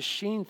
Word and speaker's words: shin: The sheen thing fascinates me. You say, what's the sheen? It's spelled S-H-I-N.